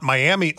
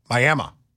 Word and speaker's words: Miami: It's not Miami, Oklahoma. Apparently down there it's pronounced Miami.